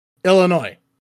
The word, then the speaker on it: Illinois: Illinois. – Like illness. Illinois.